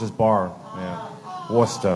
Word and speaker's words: Worcester: How do you say Worcester? – Worcester. – Worcester. How do you get that from W-O-R-S-C-E-S-T-R? How? – R's are overrated.